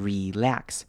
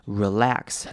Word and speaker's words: relax: 好，看到 relax 这个字有的，它的母音要特别注意，首先呢是 e 这边可以念成长母音 e 的声音，你可以念成 relax，或者是你也可以念成轻音 relax，两种声音都可以，但是后面的短母音 a 则是不可以忘记 a 的声音，记得要把那个声音发出来， relax Relax，脸颊可能会稍微用力一点。